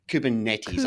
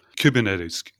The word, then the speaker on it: Kubernetes: Well, I think we start with pronunciation and different folks and different strokes, but I say it Kubernetes. I think that's the correct way to say it. – Kubernetes, definitely, as Ooh. Per the Greek word. – That's right. It's the Greek word for helmsman or the captain of a ship, which we'll touch on a little bit later there.